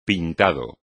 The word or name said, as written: pintado